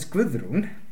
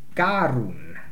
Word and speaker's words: Guðrún: You see, when the deacon addresses Guðrún, he calls her Garún. He mispronounces her name. And there's a reason for that.